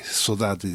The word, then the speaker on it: Saudade: Y para acompañarla, para acompañarla, porque es una compañía también, una hermosa compañía que es eh, eh, eh, eh Cesaria Évora, con Saudade, Saudade dice ella, así lo pronuncia en el portugués creol de Cabo Verde.